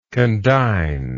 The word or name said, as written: condignly